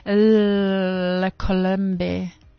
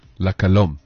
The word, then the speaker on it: La Colombe: It's La Colombe. – La Colombe. – La Colombe.